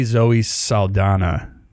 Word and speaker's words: Zoe Saldana: Apparently Zoe Saldana.